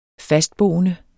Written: fastboende

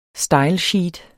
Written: stylesheet

